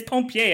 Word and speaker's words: pompier: It's pompier, I'm pretty sure.